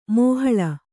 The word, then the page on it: ಮಾಹಳ: māhaḷa